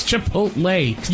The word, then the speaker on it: Chipotle: It's Chipotle.